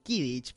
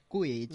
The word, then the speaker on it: Quidditch: yo lo, he escuchado a veces como Kidditch, pero no, es Quidditch.